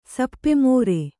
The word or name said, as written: ಸಪ್ಪೆಮೋರೆ